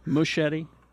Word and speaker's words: Muschietti: The guy that's directing it, his last name is Muschietti? – Yeah. Muschietti. – Machete. – Let me spell it here. – Machete. – M-U-S-C-H-I-E-T-T-I. Muschietti? – Muschietti. – Muschietti. – Yeah, okay. – Although Muschietti is good.